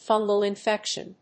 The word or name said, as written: fungal infection